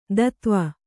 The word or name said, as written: ಧತ್ವ